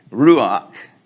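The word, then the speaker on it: ruach (רוּחַ): And the Hebrew word, ruach, in our English is spelled R-U-A-C-H.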